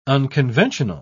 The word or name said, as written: unconventional